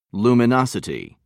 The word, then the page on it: luminosity: British English